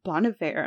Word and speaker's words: Bon Iver: I think at the beginning, and then one of my friends corrected me, and she was like, "It's Bon Iver," and I was like, "Geez, okay."